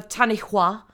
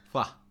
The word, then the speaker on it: Tanihua: I have to say Hua because of the putting so much emphasis on the H. Okay. I'm just saying it. Tanihua. Tanihua. Tanihua. – Tanihua. Hua. – Hua. Hua like Cool Whip.